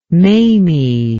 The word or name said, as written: mamie